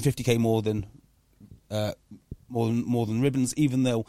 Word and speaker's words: Ribbons: So I think, like, Dave Ribbons, however you say his name, right? – Yeah, Ribbons, Ribbons.